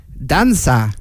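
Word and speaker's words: Dança: Hoe spreek je dat uit? Danka Semparar. – Danza. – Oh, is het een C? – Ja, het is een C. – Een hele rare C met een staartje eronder.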